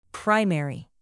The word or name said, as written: primary